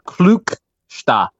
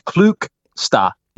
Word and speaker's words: Klukstar: and apparently it is pronounced Klukstar. There's no D at the end, and it's more of a klu as opposed to a claw.